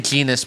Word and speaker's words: genus: Within the same genus but not the same species? – Well, genus. – That's still so different. – Genus okay. Rhymes with penis.